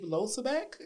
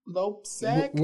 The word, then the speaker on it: Loebsack: Well, he's gotten endorsed by the uh, our Congressman Dave Losabek. I guess that's how you pronounce it. Who? – Dave Lo Loebsack. – L O E B S A C K.